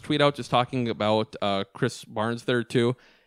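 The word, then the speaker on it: Krys Barnes: He's playing well enough that I said his name correctly this week. I now know his name, Chris Barnes. – Seriously, the amount of times you called him Burns, so disrespectful.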